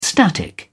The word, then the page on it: static: British English